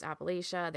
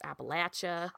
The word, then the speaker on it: Appalachia: Depending on where you are, there's Appalachia, there's Appalachia.